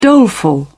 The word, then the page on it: doleful: British English